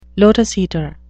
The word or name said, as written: lotus-eater